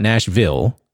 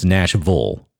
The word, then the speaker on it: Nashville: And that's not how you pronounce it. It's pronounced, we were told that when we moved here from Vegas. It's not Nashville, it's Nashville. Got it.